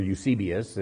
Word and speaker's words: Eusebius: Now, Eusebius, or Eusebius is how we typically pronounce it in the English world.